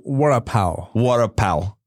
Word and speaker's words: vada pav: I hope I'm saying it right. Some vada pav. – Oh, vada pav. – Yeah. Is that Maharashtrian? Is that street food? – Vada pav. I'm saying it like in American accent.